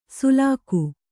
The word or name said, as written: ಸುಲಾಕು